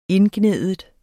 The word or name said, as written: indgnide